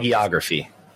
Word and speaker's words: hagiography: But you're really tempting fate by, you know, putting out this I can never pronounce that word, hiography of Vince before we even know this terrible thing that is potentially coming down the road. People were talking about hagiography.